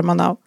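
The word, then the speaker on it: Manaus: Den heter Manau, eller Manau, alltså S på slutet, men de uttalar Manau.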